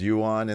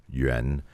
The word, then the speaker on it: Yuan: It's Yuan in the business, but the correct pronunciation is Yuan. But the thing is, people can't pronounce that YR combination.